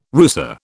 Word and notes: rusa: British English

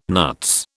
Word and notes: nuts: British English

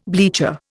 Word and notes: bleacher: Indian

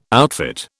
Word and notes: outfit: British English

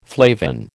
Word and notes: flavin: American English

